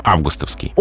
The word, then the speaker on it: августовский: И вот вы прочитали как раз с эталонным ударением, августовский.